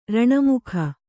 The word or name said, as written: ರಣಮುಖ